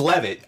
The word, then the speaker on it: Levitt: It's Levitt. I just like how you put the accent on the wrong syllable.